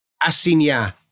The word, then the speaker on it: assignat: They started assignat. – Assignat. – Yeah, the GN, assignat in French, the GN is ny.